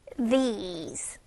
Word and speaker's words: these: This, th, this, 그리고 여기 있는 여러 개는 these, ease 하는 발음 나죠. These, this, these.